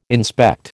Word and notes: inspect: American English

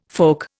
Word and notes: folk: Indian

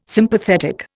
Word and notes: Sympathetic: Indian

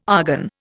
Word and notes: argon: Indian

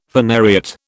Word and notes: phanariot: British English

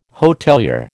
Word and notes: hotelier: American English

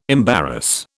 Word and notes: embarrass: British English

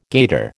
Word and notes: gaiter: American English

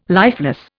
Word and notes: lifeless: Indian

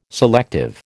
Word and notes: selective: American English

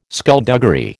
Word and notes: skulduggery: American English

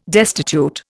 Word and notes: destitute: Indian

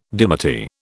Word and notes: dimity: British English

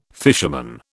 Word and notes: fisherman: British English

